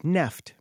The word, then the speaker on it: NFT: Although The Verge would like you to pronounce it neft, Brian.